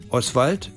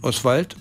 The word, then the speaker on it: Oswald: Oswald wird anders betont, Oswald, und Sie merken, das O wird schwach ausgesprochen und dann bleibt das Schwald übrig, da hinten das Wald.